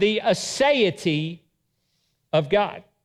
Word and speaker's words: aseity: I'd like to begin today with a theological statement on the aseity of God. Aseity, I'm going to spell it for you. It's A-S-E-I-T-Y, aseity of God.